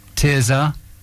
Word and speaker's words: Tirzah: Tirzah, yeah.